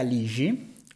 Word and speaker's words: Lixi: Detto questo, oggi vi voglio presentare un altro libro che trovo molto interessante di Luca Ligi, o che si scrive Luca Lixi,